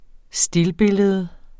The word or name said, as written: STILLBILLEDERNES